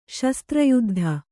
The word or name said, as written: ಶಸ್ತ್ರಯುದ್ಧ